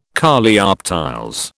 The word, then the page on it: Coleoptiles: British English